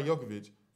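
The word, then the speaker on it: Jokić: Yeah, Jokic. Oh, my bad, sorry. – Yeah, Nikola Jokic. Jokic, I did say it wrong the first time. I said Jokic. Jokic.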